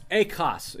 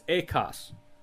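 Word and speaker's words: ekos (ἦχος): Acts chapter 2, it's Greek, it's ekos. It's spelled like echoes, but it's ekos.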